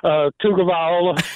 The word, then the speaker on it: Tagovailoa: How do you say Tua's last name? – Tagovailoa, Tua.